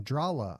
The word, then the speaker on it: Drahla: They're called Drahla, D-R-A-H-L-A. You pronounce that Drahla, right?